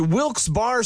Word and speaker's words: Wilkes-Barre: And you're listening to Wilkes Barre Scranton's 97.9X. Now, I had heard that it was supposed to be pronounced Wilkes-Barre. Wilkes-Barre Scranton's 97.9X. The only way to pronounce Wilkes-Barre.